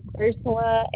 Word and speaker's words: Ursula: Ursula Aragon.